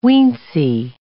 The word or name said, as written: weensy